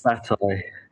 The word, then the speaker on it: Bataille: George Bataille.